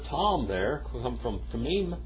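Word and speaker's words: Tamim (תמים): Tamim.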